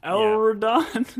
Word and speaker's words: Elrond: I think you were covering for me, but Elrond.